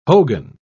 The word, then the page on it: hogan: British English